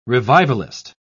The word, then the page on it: revivalist: British English